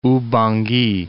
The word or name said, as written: Oubangui